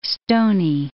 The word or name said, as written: stony